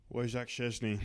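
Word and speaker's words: Wojciech Szczęsny: Wojciech Szczesny.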